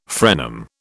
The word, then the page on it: frenum: British English